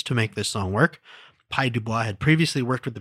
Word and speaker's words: Pye Dubois: The other one, the two other big notes that I have is, first of all, the lyrics for this are co-credited to Pye Dubois. – Yes. – I hope I'm pronouncing that name right. – That is correct. – P-Y-E.